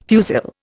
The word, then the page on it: fusil: Indian